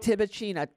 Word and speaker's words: Tibouchina: Tibicina.